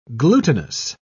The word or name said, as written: glutinous